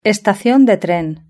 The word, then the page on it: estacion de tren: European Spanish